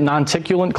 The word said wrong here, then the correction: noctilucent: cloud, right? – Noctilucent. – Nonticulant cloud? Noctilucent. Yeah, yeah. I've mispronounced it like a thousand times.